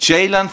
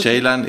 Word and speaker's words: Ceylan: Ceylan verstehe ich. Okay. Ceylan ist C-E-Y-L-A-N, dass man nicht weiß, dass es J ist oder J ausgesprochen wird, dass man eher Ceylan oder Kai, was weiß ich.